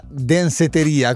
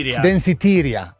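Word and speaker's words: Danceteria: Tra l'altro, a proposito di Madonna e la traccia Danseteria, come la pronuncio io, mi ha scritto ancora Franciosi, mi fa: guarda che si chiama, com'è? – Danseteria,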